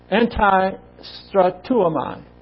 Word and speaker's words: antistrateuomai: Anti-istratuomai is A-N-T-I-S-T-R-A-T-E-U-O-M-A-I. Pronounced anti-istratuomai. And it indicates that Paul,